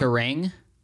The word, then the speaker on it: Kerrang: Kerrang? – Is that what it's called? Is that how you pronounce it? – K-E-R-R-A-N-G. – I was way off.